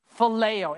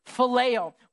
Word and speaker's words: phileo: The first Greek word of love in the New Testament is phileo. Everybody say phileo. If you struggle with that, just think of Filet-O-Fish. Same concept, same pronunciation, phileo.